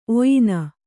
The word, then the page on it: ಒಯ್ನ: oyna